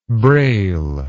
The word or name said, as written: brail